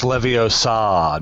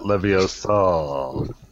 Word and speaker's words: Leviosa: It's pronounced Leviosa, not Leviosa.